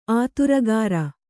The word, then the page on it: ಆತುರಗಾರ: āturagāra